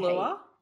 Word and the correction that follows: Beulah: Beulah, yeah. – Beulah, okay, I didn't know how to pronounce it.